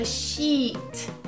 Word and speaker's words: sheet: Aqui você tem que tomar cuidado com a pronúncia, porque esses dois es, ele dá uma alongada na, no som, então fica sheet.